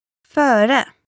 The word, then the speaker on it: Före: For words beginning with a prefix, o, för, or others, stress will fall on the second syllable. – Före.